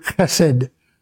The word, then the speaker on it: Chesed: K-E-E. Ki. Tov, T-O-V, Le, L-E, Olam, O-L-A-W-M, and then the word Chesed, that H with a dot under it, E-S-E-D.